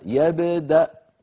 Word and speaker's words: يبدأ: فإذا الألف التي في آخر هذا الفعل يبدأ مكتوبة لكنها لا تلفظ ولا تنطق وإن وقفنا نقف يبدأ هكذا بهمزة ساكنة يبدأ